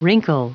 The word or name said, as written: wrinkle